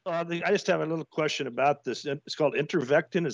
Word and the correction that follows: Ivermectin: Is that how you pronounce it? – Ivermectin. – Is it?